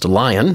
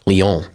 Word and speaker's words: Lyon: We're going to go to a place that looks like it's pronounced Lyon, but this is in France, and so we would call it Lyon.